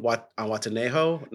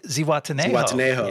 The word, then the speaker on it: Zihuatanejo: Watanejo? No. – Zihuatanejo.